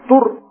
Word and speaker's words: tour: Por ejemplo, Tour se acepta así en español, T-U-R, tal como se pronuncia, no Tour, el de Francia.